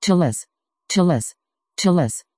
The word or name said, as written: taalas